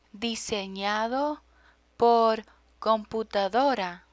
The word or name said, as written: disenado por computadora